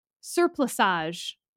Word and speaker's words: surplusage: Surplusage. Is that how you pronounce that word? – Yeah.